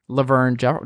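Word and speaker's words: Lauvergne: Joffrey Lavinway, how do you spell it? – Laverne. – See, it doesn't look like Laverne.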